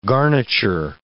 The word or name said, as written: garniture